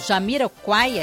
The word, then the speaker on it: Jamiroquai: Vamos ouvir Space Cowboy com Jamiroquai, é assim que pronuncia? – Exatamente.